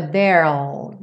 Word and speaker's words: there'll: Como o E final do there não é pronunciado, a contração do there com will, so a there'll, there'll, é aquela passagem do R para o L que acontece na palavra mundo, world.